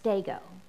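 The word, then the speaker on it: stego (στέγω): Now, when we hear the word that love bears all things, that word bears, if we were to look it up in the Greek, it's pronounced this, stego.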